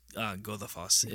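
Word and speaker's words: Goðafoss: It's actually just Godafoss. Godafoss. That what looks like a D, depending on where you're looking, it's actually a... it's a special character called Ev, which is basically like a, they call it a voiced sort of TH sound.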